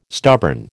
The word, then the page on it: stubborn: American English